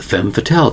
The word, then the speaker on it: femme fatale: They are called femme fatale type of woman. Femme fatale. Two words. First word is femme, one syllable, F-E-M-M-E. The second part is F-A-T-A-L-E, fatale.